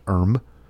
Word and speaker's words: IRM: It's written IRM in caps, and as far as I know, it's pronounced Erm.